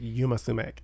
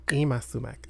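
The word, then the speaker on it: Yma Sumac: So I perform, and I always say her name wrong. I pronounce it Yuma Sumac. – Ima Sumac.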